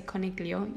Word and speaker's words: coniglio: So for example, dog is cane, spelt C-A-N-E. Cat is gatto, G-A-T-T-O. Rabbit is coniglio, C-O-N-I-G-L-I-O. But you never pronounce the G. You don't say coniglio, you say coniglio. GL becomes gl. Fish is pesce, P-E-S-C-E. And then guinea pig is porcellino d'India. Porcellino d'India. P-O-R-C-E-L-L-I-N-O D apostrophe I-N-D-I-A.